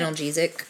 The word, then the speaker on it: analgesic: And it also suggests that virgin coconut oil has anti-inflammatory and analgesic, is that how you say it? – Analgesic.